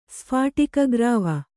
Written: ಸ್ಫಾಟಿಕಗ್ರಾವ

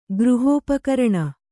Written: ಗೃಹೋಪಕರಣ

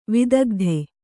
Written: ವಿದಗ್ಧ

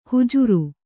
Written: ಹುಜುರು

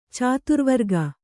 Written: ಚಾತುರ್ವರ್ಗ

